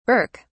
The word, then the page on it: birk: British English